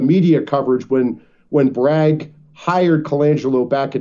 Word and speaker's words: Colangelo: His name is Matt Colangelo, is I think how I pronounce his last name. It's C-O-L-A-N-G-E-L-O, Colangelo.